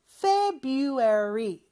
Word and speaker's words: February: 1월은 January, 2월은 February, 물론 February라고 철자는 되어 있지만 그 R 발음, 첫 번째 R 발음은 하지 않는다고 했어요. February 이렇게 발음하면 되고요.